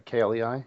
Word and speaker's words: Klei: That's Klei Entertainment, right? KLEI, is that how you say it? It is, yes.